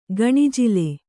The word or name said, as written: ಗಣಜಿಲೆ